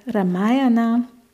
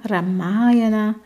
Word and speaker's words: Ramayana: Und somit heißt Ramayana mit einem langen A, Ramayana, bedeutet eigentlich Ramas Reise oder Ramas Gang. Ja, die Aussprache habt ihr jetzt schon ein paar Mal gehört. Ich habe oft gehört Ramayana. Und da wurde unser Professor an der LMU immer ganz fuchsteufelswild.